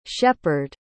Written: shepard

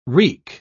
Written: wreak

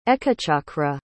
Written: Ekachakra

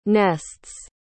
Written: nests

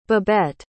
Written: babette

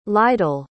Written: lydell

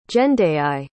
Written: Jendayi

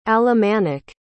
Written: alemannic

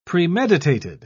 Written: premeditated

